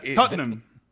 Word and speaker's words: Tottenham: Tottenham.